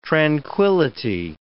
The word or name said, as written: tranquility